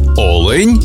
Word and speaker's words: олень: і в однині, і в множині наголос падає на перший склад – олень та олені.